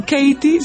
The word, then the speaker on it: Katie: Bueno, Katie se escribe K-A-T-I-E.